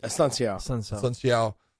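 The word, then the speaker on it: Assunção: Unsakao. I can't say his name. – Asunciao gets a win against him.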